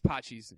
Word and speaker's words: Pachi's: Yeah, this is Pachi's, Patixi's, if you're trying to spell it on the internet. – I thought it was Paxtis or Paxtis. – No, I'm just saying that's how I'd say it if I had to, but it's Pachi's is how you pronounce it.